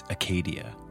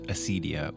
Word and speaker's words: acedia: That vice is acedia, sometimes pronounced acedia or acedia, literally not caring in the Greek.